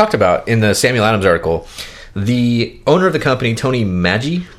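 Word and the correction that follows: Magee: I'm probably mispronouncing his name. – Mr. Lagunitas. – Tony McGee.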